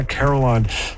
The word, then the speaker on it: carillon: That's the correct pronunciation, a carillon.